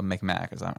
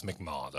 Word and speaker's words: Mi'kmaq: One is naturally racist as all get out, a spurned Mi'kmaq woman, and that's as a Mi'kmaq. Am I pronouncing that correct? I know there's two. – So they spelled it, I think, improperly. They shouldn't. It's McMahon.